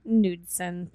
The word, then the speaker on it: Knudsen: And that was for Robert Knudsen? K-N-U? – Knudsen? – Knudsen. Thank you. Maybe. The K is silent. Probably you're right.